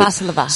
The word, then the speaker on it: Valsalva: She was doing something called the, I'm going to butcher this, Valsalva? What is that? I can't pronounce it. – Valsalva.